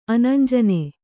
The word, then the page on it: ಅನಂಜನ: anañjana